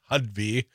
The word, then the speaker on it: Hovde: It's Hudby. – Hudby, yes. – I was calling him Hudby, but, you know, yeah, emphasis on the ho, like sell out, you know, Laguna Beach, sell out ho.